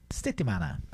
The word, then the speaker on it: settimana: É semana isso a pronúncia? – Settimana. – Hã? – Settimana. – Settimana.